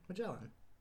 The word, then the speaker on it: Magellan: Are you sure that's how you say that? – Yeah. How would you say it? – I'm just asking. – Okay. It's like The Explorer, right? Magellan.